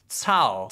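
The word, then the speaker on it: Cao: Tao is like a T-S-A-O. In but it's C-A-O. – But you'd pronounce it Tao.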